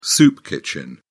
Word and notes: soup kitchen: British English

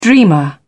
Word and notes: dreamer: British English

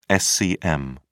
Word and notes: scm: British English

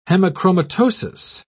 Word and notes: hemochromatosis: British English

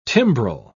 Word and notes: timbrel: British English

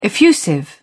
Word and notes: effusive: British English

